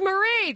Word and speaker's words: Marie: Mari, am I not saying your name right? – It's Marie. – It's Marie. The E fell off. I'm super sorry. Hey, Marie.